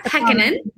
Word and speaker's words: Pekkanen: Sarah Pekkanen. I always say her name wrong. Sarah Pekkanen, right? – Yes.